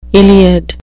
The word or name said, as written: Iliad